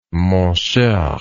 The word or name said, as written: mon cher